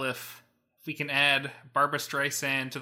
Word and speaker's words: Streisand: So Barbra Streisand was interviewed recently, and I guess Siri says her name wrong. It says it with like a hard, like with a Z instead of a soft S.